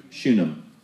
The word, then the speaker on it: Shunem: How do you pronounce that? – Shunem.